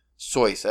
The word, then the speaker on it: Seuss: It's Dr. Seuss as we know him, but I'm pretty sure he pronounced it Seuss, as in, like, noise almost, or soy sauce.